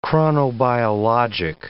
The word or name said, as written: chronobiologic